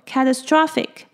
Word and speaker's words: catastrophic: 那如果是形容词的话呢，它重音是在 TRO 的部分，所以它念作 catastrophic。